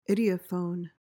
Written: ideophone

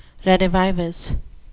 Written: redivivus

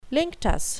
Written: linctus

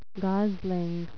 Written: gosling

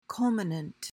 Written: culminant

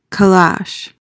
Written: calash

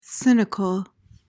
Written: cynical

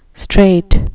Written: strait

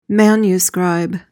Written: manuscribe

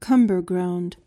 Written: cumberground

